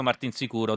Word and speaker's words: Martinsicuro: Martinsicuro.